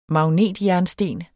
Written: magnetjernsten

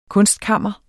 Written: kunstkammer

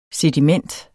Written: sediment

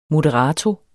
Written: moderato

